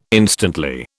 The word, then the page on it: instantly: British English